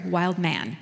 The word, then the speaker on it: Wildman: I had the privilege of being part of an interview with Wildman. It's spelled Wildman, but he pronounced it Wildman, so I'm going to try to.